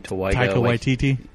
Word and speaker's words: Taika Waititi: My favorite episode is the last one from, however you say his name, Taika Waititi.